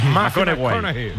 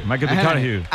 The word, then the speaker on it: McConaughey: Hay un futbolero, le gusta que hay que ir corrigiendo la pronunciación. – McConaughey. McConaughey. Bueno, pues el Matías.